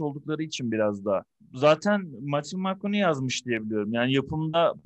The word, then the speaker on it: Matthew McConaughey: Ya şöyle True Detective'i izledim ama ben orada daha çok Matthew McConaughey değil mi? Adı böyle okunuyor.